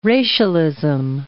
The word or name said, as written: racialism